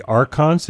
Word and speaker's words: Archons: This one is from The Return of the Archons, is that how they say it in the movie? – Yeah.